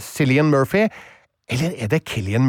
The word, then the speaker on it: Cillian: Mener jeg har googla det at det er Killian, det er Killian, fordi jeg har gjort Killian, samme feil.